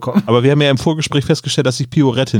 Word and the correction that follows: Pirouette: nicht richtig aussprechen kann. – Pirouette, ja, genau. Pirouette.